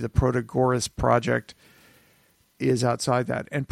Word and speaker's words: Protagoras: Oh, Protagoras.